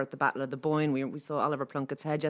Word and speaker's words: Bóinne: Am I pronouncing this right? Bóinne, Bóinne? – That's correct.